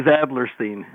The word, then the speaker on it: Adlerstein: The last one is Adlerstein.